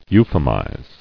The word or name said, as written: Euphemized